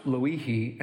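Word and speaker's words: Lo'ihi (Lōʻihi): Yes, it's pronounced Lo'ihi, and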